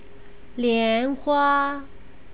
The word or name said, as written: lianhua